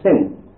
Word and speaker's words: sin: And if you put it on the dexter side, it is pronounced sin.